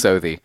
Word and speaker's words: Southie: I think it's Southie.